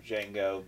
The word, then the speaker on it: Django: Django, the D is silent.